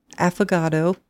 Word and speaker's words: affogato: Affogato.